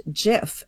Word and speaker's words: GIF: But he very explicitly then and since then repeatedly has said that it's pronounced GIF, and they had in their office a slogan that choosy programmers choose GIF.